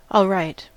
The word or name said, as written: alright